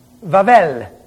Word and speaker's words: Babel: He tried that way back in a place called Vavel. Only you pronounce it funny. You'd call it Babel.